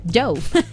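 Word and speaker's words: Djo: Vámonos con más música, llega Joe Carey, Matcher, el actor Joe Carey, que su nombre es Joe, pero su nombre artístico se escribe D-J-O y se pronuncia igual, Joe.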